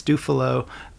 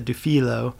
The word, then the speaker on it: Dufilho: Which is when I was really young, you know, people have always mispronounced my last name. It's Dufilho. Some people pronounce it Dufilho. Either way, the spelling is really tricky, and especially, you know, if you're like in first grade.